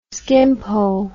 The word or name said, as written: skimpole